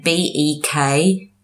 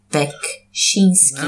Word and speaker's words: Beksiński: There's a lot of Z's in there. His first name is Zdzisław. Surname Beksiński. Beksiński.